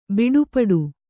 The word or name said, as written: ಬಡಪಟ್ಟು